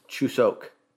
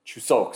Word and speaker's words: Chuseok: The proper pronunciation of the holiday is Chuseok. – Chuseok.